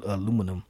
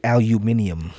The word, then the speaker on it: aluminium: the British would say, aluminium. Uh-huh. That's the easiest way to say it right there. Aluminium. That is that's not country, that's British.